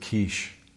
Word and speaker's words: quiche: The ladies' breakfast, what do you rhyme with quiche? Sheesh.